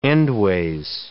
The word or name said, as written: endways